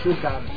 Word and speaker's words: Sucre: Se escribe Sucre, se dice Sucar.